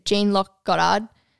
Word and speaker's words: Jean-Luc Godard: So this one was done by Jean-Luc Godard. I think I've butchered his name, but I'm not French, so what can I do? – I think you got it.